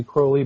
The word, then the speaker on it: Crowley: Yeah, I mean, I think Crowley rhymes, his poem, it was The Beetle, he rhymes Crowley with holy. I think if you abide by his pronunciation, it would be Crowley, but Ozzy Osbourne pronounces it Crowley, so depends on who you rely upon, I guess.